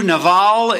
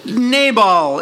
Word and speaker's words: Nabal: Probably more accurately in the Hebrew, Naval is probably the better way to pronounce his name if we were to speak Hebrew. But we speak English, so we translate names into the way we can say them. So Nabal is what you're probably familiar with.